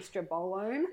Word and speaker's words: estrobolome: How do you say it? Estrobolome. – Estrobolome, I tend to say.